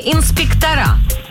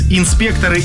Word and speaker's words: инспекторы: Пятый вопрос, инспекторы или инспектора, вы выбрали второй вариант, а правильно говорить инспекторы. Инспектора — это допустимая версия, да, но не та, которую нужно было назвать.